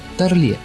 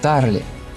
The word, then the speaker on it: Тарле: Как-то на большой лекции в МГИМО его назвали Тарле, с таким французским ударением, и он вдруг вскипел: «Я не француз, а еврей, и моя фамилия произносится Тарли».